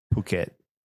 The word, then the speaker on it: Phuket: I never knew how to pronounce that, the name of that town. Obviously it's spelled like fuck it, but I'm like, there's no way that's what it is. – I'm pretty sure it's Phuket, but have you ever been there? – In Thailand?